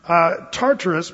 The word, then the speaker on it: Tartarus: The word is Tartarus. If you spell it in the English transliteration, it's T-A-R-T-A-R-U-S. Tartarus.